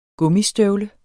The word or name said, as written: gummistøvle